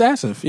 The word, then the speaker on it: Asaph: It's Asif, yeah. Asif.